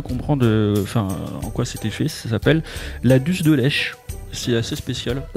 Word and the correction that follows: dulce de leche: La dulce de leche.